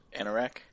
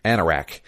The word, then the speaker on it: Anorak: So he would go and like go into their, since he had the robes of Agnarok, how's it pronounced? – Anorak. – Anorak. The robes of Anorak.